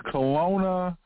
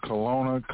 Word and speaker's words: Colona: And that's Kelowna, Colorado. Kelowna, Colorado. That's spelled C-O-L-O-N-A, one word, Kelowna, Colorado.